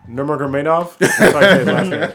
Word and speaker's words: Nurmagomedov: Because the fights that I want to see Tony Ferguson versus Khabib Nurmagomedov. I can't even say it right now. – God damn. – Nurmagomedov.